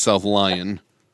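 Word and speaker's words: South Lyon: Yeah, you said South Leone and it's South Lion.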